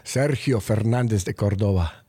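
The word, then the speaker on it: Sergio Fernández de Córdova: You know I'm going to make you pronounce it with the proper Spanish accent. – Sergio Fernandez de Cordova.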